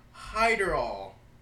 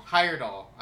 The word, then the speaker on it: Heyerdahl: All right, uh, Thor Hyderall. – Give me that. you thought I was making it up? yeah. Thor Heyerd- Heyerdahl? – Heyerdahl.